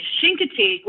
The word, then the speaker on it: Chincoteague: Actually, Chincoteague, which is how it's pronounced,